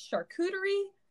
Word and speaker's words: charcuterie: You can tell we're in for it because, A, who knows how to properly pronounce charcuterie? Not me, okay? – I know, but it's actually charcuterie.